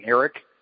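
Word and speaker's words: Ereck: Is that how you say his name, Arik? – Yeah, I've heard it pronounced both ways, but it looks like a lot of telecasts are saying Eric. We actually talked to him or his family. It's more Arik.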